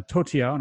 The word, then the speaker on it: Toutiao: Now, Toutiao, and I'm pronouncing that right because we looked it up, is essentially a news-based search engine.